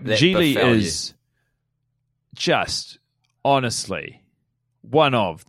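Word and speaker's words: Gigli: It's Ben Affleck plays a hitman named Larry Zhili, pronounced like really, which he has to address.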